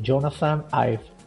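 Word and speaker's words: Jonathan Ive: pues bien pronunciado, es algo así como Jonathan Ives.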